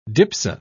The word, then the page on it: dipso: British English